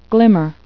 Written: glimmer

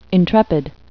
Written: intrepid

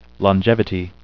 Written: longevity

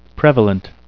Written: prevalent

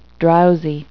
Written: drowsy

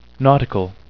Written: nautical

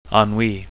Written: ennui

